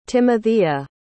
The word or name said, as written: Timothea